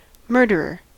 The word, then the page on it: murderer: American English